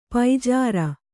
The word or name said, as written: ಪೂಜಾರಿ